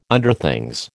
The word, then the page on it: Underthings: American English